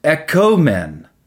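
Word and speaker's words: ekomen (ἔχωμεν): If you change it from ah to O, then now it's ekomen, not ekamen.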